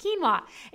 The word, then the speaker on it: quinoa: You know that word that you see and you can't pronounce it? It's pronounced quinoa.